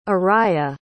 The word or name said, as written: ariyah